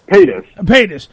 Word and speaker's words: Paytas: He had to get a date with Trisha, and I can't ever pronounce her last name. Pius? – Paytas. – Paytas. P-A-Y-T-A-S.